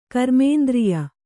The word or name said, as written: ಕರ್ಮೇಂದ್ರಿಯ